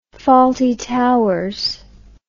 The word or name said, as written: fawlty towers